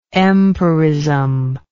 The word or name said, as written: empirism